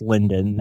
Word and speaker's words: Lyndon: Or you can look for me under my name on Facebook, which is Chris Lyndon.